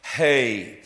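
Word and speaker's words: He: It is, we would say H-E-H, but it is the Hebrew letter pronounced hey.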